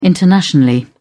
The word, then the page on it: internationally: British English